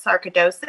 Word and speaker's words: sarcoidosis: You were diagnosed with a rare form of the disease sarcoidosis. Am I saying it right? – Yes. Thank you. Well done.